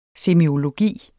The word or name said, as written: SEMIOLOGI